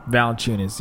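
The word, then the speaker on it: Valančiūnas: So, is Jonas Valanciunas, how do you say it? Valanciunas? – Valanciunas, yeah.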